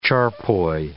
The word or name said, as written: charpoy